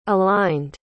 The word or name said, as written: aligned